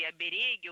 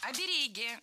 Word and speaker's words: оберег: Раньше допускалось ударение только на первом слоге – оберег, а сейчас возможны два варианта – оберег и оберег.